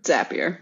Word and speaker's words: Zapier: How do you say it? Zapier? – Zapier. – Now you scrambled ZPA. – Me. – Zapier. – Rhymes with happier.